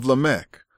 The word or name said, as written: Lamech